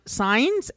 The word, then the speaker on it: Saenz: It's pronounced Saenz, S-A-E-N-Z.